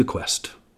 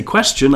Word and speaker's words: question: Because if I have the T-I-O-N in my head that I'm taught as a structure, I don't see the quest. And when I say question, I don't say quest. The base is pronounced quest with what is called a CH sound.